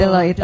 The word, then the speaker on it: Deloitte: Nah ini barusan dari Deloitte, ya sih. Iya, itu ya bacanya. – Betul. – Deloitte.